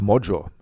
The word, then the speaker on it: Magyar: Sein Herausforderer, Peter der Ungar, so heißt er, Peter Magyar, ausgesprochen wird es Mojo, ja, Magyar, die Magyaren, das sind die Ungarn.